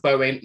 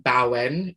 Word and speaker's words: Bowen: Hi, my name's Natasha Bowen. Bowen is a Welsh name. My mum got her own way and named me. It's pronounced Bowen, not Bowen, which I often get.